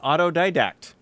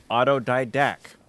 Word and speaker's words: autodidact: Jennifer says she is an autodidact. Paul pronounced it autodidact. All right, so your pronunciation was off.